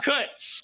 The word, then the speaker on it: Kutz: Sam Kut. Kutz. – How do you spell that? – K-U-T-Z.